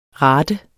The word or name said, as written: rate